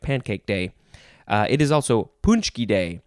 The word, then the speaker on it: paczki: That is how you say that word, but that is not how it is spelled to my brain. Or it's spelled differently than how it's pronounced, because it's a Polish word. It is spelled P-A-C-Z-K-I. Punchki.